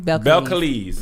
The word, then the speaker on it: Belcalis: Belcalis. Is that how you pronounce it? – Mm-hmm. – Belcalis.